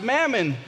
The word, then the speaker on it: mammon: In fact, that word money in his original language is pronounced mammon, which actually refers to one's person's all of their material possessions.